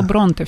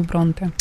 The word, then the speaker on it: Бронте: Эмили Бронте, я правильно? – Я бы сказала Бронте все же.